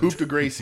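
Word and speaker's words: coup de grâce: The coup de grace, ladies and gentlemen. – If Jenna, that is how it's pronounced, thank you for mentioning that on Twitter.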